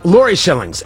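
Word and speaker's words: Lauri Shillings: Laura Shillings. Lori Shillings. L-A-U-R-I.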